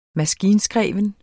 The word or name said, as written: maskinskreven